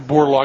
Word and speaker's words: Borlaug: You have a date for Norman Borlaug? – I believe September.